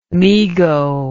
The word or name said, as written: mego